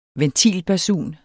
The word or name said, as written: ventilbasun